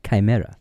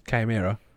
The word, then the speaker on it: Chimera: How do you say that word? – Chimera. – Chimera. Like the band Chimera.